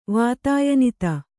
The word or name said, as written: ವಾತಾಯನಿತ